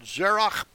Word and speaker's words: Zerach: Now, I'm using the proper Hebrew pronunciation for the names. I'm not anglicizing anything. It's wrong. It's always been wrong. And I'm not going to repeat those errors. I'm going to say it right. Okay, Peretz and Zerach by Tamar.